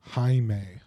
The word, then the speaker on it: Jaime: Yeah, so the J is silent. A-I-M-E. – Jaime.